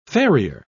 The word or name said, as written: farrier